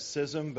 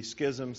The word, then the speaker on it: schism: And I'm aware a lot of people pronounce that schism, but in the Greek it would be schism, so I'm going with that.